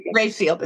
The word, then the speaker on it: Raphael: And I loved how you depicted your father's relationship with, I think his name was Raphael. Raphael? – It was Raphael. Yeah. Rayfield, as if it were R-A-Y-F-I-E-L-D, that was spelled like the painter Raphael. – Sorry, so, but we called him Rayfield.